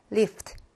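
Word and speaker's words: 리프트 (лифт): 리프트.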